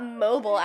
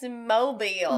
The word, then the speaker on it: Mobile: It's like, it's not Mobile, Alabama. It's Mobile.